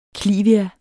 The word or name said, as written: klivia